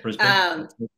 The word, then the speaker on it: Brisbane: Brisbane. Am I saying that right? Can I get some clarification? – Yeah, Brisbane.